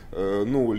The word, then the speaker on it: GNU: LAMP is a combination of GNU Linux, so that's why G here is not pronounced, is silent,